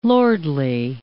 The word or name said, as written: lordly